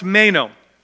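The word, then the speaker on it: meno (μένω): It's pronounced meno.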